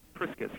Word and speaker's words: Priscus: Priscus, yeah.